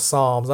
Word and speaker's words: Psalms: I didn't even know how to pronounce Psalms. I'm saying, you know, pronouncing the P in a regular way or whatever.